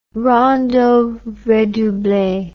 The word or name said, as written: rondeau redouble